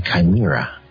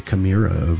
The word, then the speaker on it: chimera: When you take two different species and you create a new creature, a chimera. Am I pronouncing that correctly? I think so. – Yes, yes, that's it.